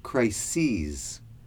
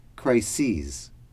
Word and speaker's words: crises: The second word I want you to pay attention to is crises. That is the plural of crisis. Listen to the pronunciation. One crisis, two or more crises. Crisis, crises. The third word is acute.